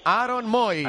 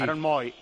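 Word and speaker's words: Aaron Mooy: Ya sé que se escribe con dos os, pero está pronunciado bien, ¿eh? Aaron Moy. – Aaron Moy.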